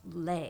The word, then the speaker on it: Leigh: Like Leigh, yeah. But her parents changed the spelling of their last name, yeah. So it's actually pronounced Leigh.